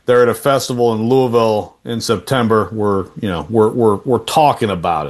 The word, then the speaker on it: Louisville: Louisville, that's right.